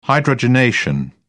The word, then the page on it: hydrogenation: British English